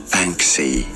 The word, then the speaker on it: Banksy: Banksy. – Oh, Banksy.